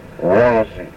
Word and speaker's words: Wazi: Incidentally, Wazi, pine tree, is a separate word pronounced Wazi. Accent is on the first syllable. Wazi.